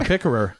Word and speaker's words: pickerer: A pickerer? – A pickerer. That is actually how it's said.